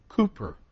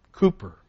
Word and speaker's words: Cowper: It's spelled C-O-W-P-E-R in our hymnal. Wrote, "There is a fountain filled with blood," the English songwriter. The English say Cooper. Cooper. Now we would say double O. And the W sometimes has a double O sound to it, a long O. In certain situations. This is one of them. R-U-W-M, room.